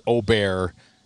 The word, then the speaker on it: Åberg: It was Åberg, Åberg. It's Åberg is the Swedish pronunciation. – Yes. – Actually, it's Åberg is how they pronounce it.